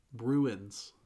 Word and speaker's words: Bruins: Bruins. – Bruins. – Like you brew tea.